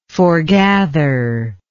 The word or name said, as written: foregather